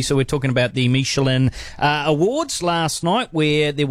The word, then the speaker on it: Michelin: Because I said Michelin instead of Michelin, and they said that if I say it again, they would like to eat my liver with some fava beans.